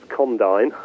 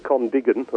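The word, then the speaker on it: Condign: Lots of people don't know how that word is pronounced, but it's my understanding it's Condyne, as opposed to Condigan or some other bizarre.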